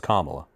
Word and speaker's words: Kamala: He speaks pretty eloquently, but sometimes, And he's got a female, Kamala Harris, or Kamala, I'm sorry. How do you say her name? And I'm not trying to make fun. I really struggle with this, and I know maybe I shouldn't. It's Kamala. The first female vice president.